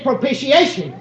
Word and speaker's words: propitiation: You don't hear the T's in the correct pronunciation of that word. It's propitiation.